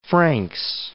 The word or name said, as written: Franks Peak